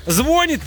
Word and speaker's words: звонит: Потому что ударение в слове «звонит» или «звонит» в будущем изменится и начнет падать на первый слог.